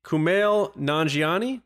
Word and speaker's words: Kumail Nanjiani: First story, Obi-Wan Kenobi, Kumail Nanjiani. Did I say that right? – I believe so, yeah.